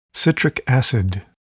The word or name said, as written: citric acid